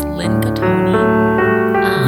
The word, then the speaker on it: Lin Gothoni: You can also find me on Instagram as Lynn Gothoni.